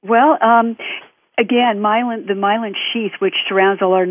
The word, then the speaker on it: myelin: Well, we were just talking about the myelin sheath.